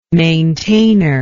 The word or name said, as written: maintainor